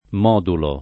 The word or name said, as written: modulare